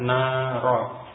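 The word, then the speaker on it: naro (نَارَ): Walaupun tulisannya mungkin sama, tapi ini fi'il maka dibaca naro. Kalau isim narun api atau neraka itu narun, tulisannya sama. Tapi jenis katanya berbeda, yang satu isim yang fi'il, membacanya juga berbeda ya. Narun dengan tanwin dhamma, adapun naro ini fi'il, huruf akhirnya fathah, fi'il madhi.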